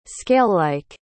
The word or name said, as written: scalelike